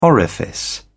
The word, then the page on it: orifice: British English